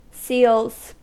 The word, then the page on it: seals: American English